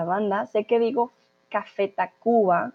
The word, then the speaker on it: Tacvba: Entonces, digo Café Tacuba con una U, sin embargo, se escribe con V, para que no piensen que escribí mal la U, simplemente es la forma en como la banda se hace llamar.